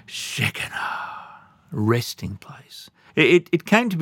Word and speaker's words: Shekinah: Now, it's either, in English, it's either Shekinah or Shekinah. That's the English or the American English pronunciation. But I like the Hebrew pronunciation better. You need to say it with me to get the full effect. Shekinah, that's how they say it, Shekinah.